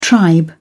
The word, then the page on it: tribe: British English